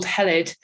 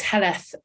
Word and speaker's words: Heledd: Yes, it's a name that is often mispronounced because it's spelt with a double D at the end. So often get called Heledd, but it's Heledd as pronounced in Wales.